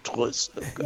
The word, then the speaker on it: Trezeguet: Vabbè, Trezeguet mi sembra un po' all'italiana, però potevi dargli un po' di R arrotata in più. Trezeguet, come direbbe l'avvocato, Trezeguet.